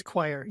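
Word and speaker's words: Quire: Yes, it is Choir. Yeah, it's spelled funny, but it's Choir. – It is spelled funny. Yeah, it's got a key. It starts with a Q.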